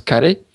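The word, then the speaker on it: kare (カレー): Donc, au Japon, ça, ça se prononce kare ».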